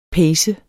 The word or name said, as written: pace